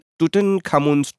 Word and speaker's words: Tutankhamun: Egypt se 4,000 years ago in Tutankhamun's tomb. Am I pronouncing it correctly? – Yeah, Tutankhamun. Tutankhamun yeah.